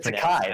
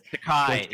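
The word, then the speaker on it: Takei: It's sort of reminiscent of earlier this week when George Taki, I mispronounced Takai, it. Right? – Takai, yes.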